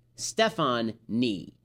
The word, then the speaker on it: Stefonknee: Stephanie is spelled S-T-E-F-O-N-K-N-E-E. Stefan Nee.